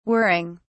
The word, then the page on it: whirring: British English